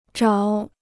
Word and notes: 找: zhǎo

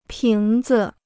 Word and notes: 瓶子: píng zi

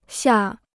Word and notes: 下: xià

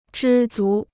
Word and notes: 知足: zhī zú

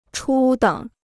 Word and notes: 初等: chū děng

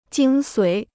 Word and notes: 精髓: jīng suǐ

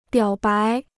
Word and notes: 表白: biǎo bái

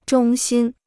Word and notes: 衷心: zhōng xīn

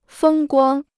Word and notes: 风光: fēng guāng

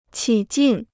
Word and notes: 起劲: qǐ jìn

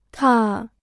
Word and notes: 踏: tà

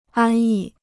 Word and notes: 安逸: ān yì

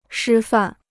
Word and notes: 师范: shī fàn

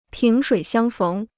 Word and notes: 萍水相逢: píng shuǐ xiāng féng

